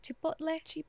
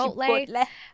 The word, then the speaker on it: Chipotle: Like, I can't say it because I used to say like Chipotle. But now Chipotle. It's Chipotle.